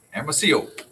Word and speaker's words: Hermosillo: Hermosillo.